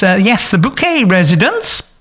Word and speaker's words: Bouquet: Of course, the star was Patricia Rutledge as Hyacinth Bouquet, who insists it's pronounced bouquet, and Clive Swift as her long-suffering husband, Richard.